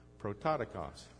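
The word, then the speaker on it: prototokos (πρωτότοκος): Say it together with me, prototokos, Greek scholars, right here you go.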